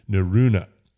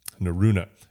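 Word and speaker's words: Norrøna: It's Neruna. Neruna. There's the slash through the O, the second O in the word. But we got some really cool kind of fall-oriented clothing from Neruna.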